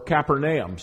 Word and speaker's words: Capernaum: and he headed for Capernaum in Luke chapter 4, or Capernaum. Some may want to pronounce it that way.